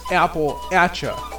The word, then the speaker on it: Appalachia: Speaking of the pronunciation of Appalachia, Gemlog, I heard someone say the correct way to pronounce it is Appalachia, like throwing an apple at you.